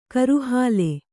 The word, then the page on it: ಕೋರೆಹಲ್ಲು: Kannada